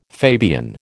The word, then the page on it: Fabian: American English